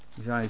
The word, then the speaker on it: Jesaja: Jesaja 24, vers 20. Zeg ik dat goed? Ja. Jesaja 24 vers 20.